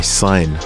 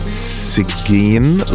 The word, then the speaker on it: Cygn: I only actually discovered how to pronounce his name recently. For the longest time, I was like, Sigin? Like, I didn't even pronounce his name on the show a couple of times because I want to get it wrong. He spells it C-Y-G-N. So shout out to Sign.